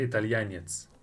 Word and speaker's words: Italianets: How do I say Italian? Italianski? – Italianski. – Italianski. Mushini? – Mushina. – Mushina? – You can say Italianets.